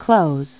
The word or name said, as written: close